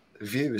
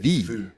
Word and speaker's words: Vue: And view is not the correct way to pronounce it. – Vue.